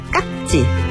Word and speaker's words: 깍지: 연필 깍지, 발음은 깍지, 쓸 땐 깍지로 표기합니다.